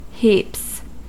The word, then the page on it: heaps: American English